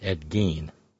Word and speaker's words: Gein: Ah, so it was Ed Gein. Thank you. Uh, th- that's Ralph serving as the Horn Ad Hoc Serial Killer Research Department, Ed Gein. I pronounced it like it was German.